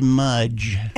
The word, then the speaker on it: Mudge: David Mudge. It's spelled like fudge, but Mudge.